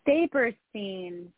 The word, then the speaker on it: Saperstein: I like the one with Larry, help me pronounce his name, Saperstein? – Saperstein.